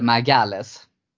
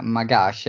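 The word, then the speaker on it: Magalhães: Fick ju lära mig då när vi hade Filip från Göteborg som gäst att det skulle vara mag, mag, mag, Magage eller någonting sånt där, att man uttalar det på portugisiska.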